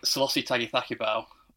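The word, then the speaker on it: Sailosi Tagicakibau: I think Selassie Tagithakibau. I'll probably pronounce that slightly wrong. But no, you've done it right.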